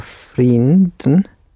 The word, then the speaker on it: vrienden: Make it a nice long E for the vriend. – Haar vrienden blijven niet lang.